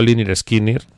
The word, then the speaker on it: Lynyrd Skynyrd: nunca sé cómo se pronuncia, así que yo digo Lynyrd Skynyrd. – Muy bien. – Los Lynyrd Skynyrd sacan Sweet Home Alabama y lo hacen como respuesta a Alabama de Neil Young, acusándole, por cierto, Lynyrd Skynyrd,